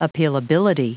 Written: appealability

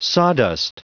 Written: sawdust

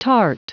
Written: tart

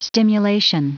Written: stimulation

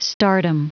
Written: stardom

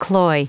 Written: cloy

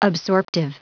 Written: absorptive

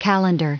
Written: calendar

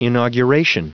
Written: inauguration

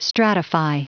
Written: stratify

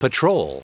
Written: patrol

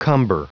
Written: cumber

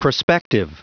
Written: prospective

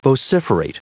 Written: vociferate

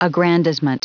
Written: aggrandizement

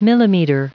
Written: millimeter

